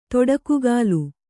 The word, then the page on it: ತೀಟಕೋಗಿಲ: tīṭa kōgila